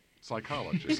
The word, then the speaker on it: Psychology: On my right, from the Department of Psychology. That's where it's from, yes. – P is silent.